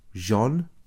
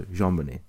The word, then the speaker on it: JonBenet: Because when you first told me about this, I thought you were saying Jean. No, at first I thought you were saying John. – JonBenet Ramsey. – It's like, it's spelled like Jean Bennett. But it's French, so JonBenet.